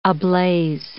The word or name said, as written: ablaze